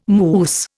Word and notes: mousse: Indian

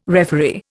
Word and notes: referee: Indian

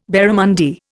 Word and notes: barramundi: Indian

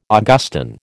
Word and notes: Augustan: American English

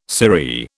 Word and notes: sirree: British English

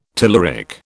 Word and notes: telluric: British English

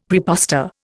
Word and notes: praepostor: Indian

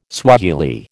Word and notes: Swahili: American English

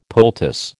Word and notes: poultice: American English

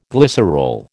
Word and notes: glycerol: American English

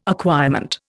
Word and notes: acquirement: Indian

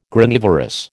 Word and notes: Granivorous: American English

